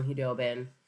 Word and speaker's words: Khudobin: They have good goaltending with Ben Bishop and Anton Khudobin, who I either call Qdoba, because that's what his name sounds like,